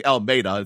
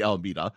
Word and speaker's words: Alameda: Number one, I kept saying Alameda. It's actually Alameda.